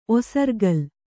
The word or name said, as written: ಒಸರ್ಗಲ್